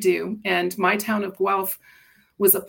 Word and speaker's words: Guelph: So, born and raised in Guelph, Ontario, is that right? – That is correct.